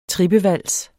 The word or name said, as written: trippevals